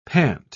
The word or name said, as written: pant